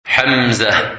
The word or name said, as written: Hamzah